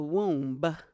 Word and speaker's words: womb: When I was in the womb, she ate. – You just pronounced the B in womb? – Womb.